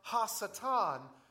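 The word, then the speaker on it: ha-satan (הַשָּׂטָן): And in the Hebrew, it's pronounced ha-satan, which literally means prosecutor.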